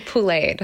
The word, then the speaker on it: poulet: It's to be poulet. So it's a poulet is the word for chicken in French. It's spelled P-O-U-L-E-T.